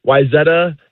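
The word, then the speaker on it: Wayzata: So many people say Wyzetta. Wyzetta is correct.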